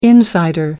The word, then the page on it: insider: American English